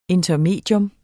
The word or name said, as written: intermedium